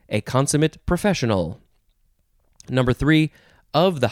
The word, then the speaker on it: consummate: Next word is the second form of this, but it is pronounced consummate.